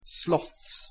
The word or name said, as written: sloths